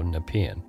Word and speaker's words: Nepean: Now I know how to say Nepean. Because I have been mispronouncing it every time I've had to say it on the show. So Nepean.